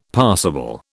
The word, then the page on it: passible: British English